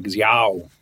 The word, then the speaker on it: Xiao: It's spelled X-I-A-O. That's how it's... – It's not pronounced like that, is it? – I have no idea. I'm going to have to ask my friend, my Chinese friends. I have one. – Xiao. – Yeah, it's like Xiao.